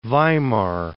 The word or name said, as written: Weimar